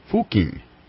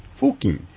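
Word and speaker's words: Fucking: How would you like to have a town, be a town, and have your name pronounced, and this is the way it's pronounced, Fuking. However, it's spelled differently, to a more colloquial, perhaps a profane way, is the way they spell it, but it's pronounced Fuking.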